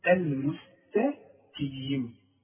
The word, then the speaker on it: المستقيم: القاف من الحروف المفخمة. المستقيم.